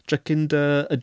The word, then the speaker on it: Jacinda: Jacinda.